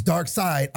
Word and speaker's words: Darkseid: But now, you're giving them Darkseid, which it's Darkseid. I'm sick and tired of people saying Darkseid. Stop. – Darkseid? Who the fuck is saying that? It's Darkseid. – Darkseid. – Even though it's spelled... S-E-I-D. It's not Sade or Seed.